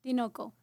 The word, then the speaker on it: Tinoco: Tinoco. But yeah, Tinoco.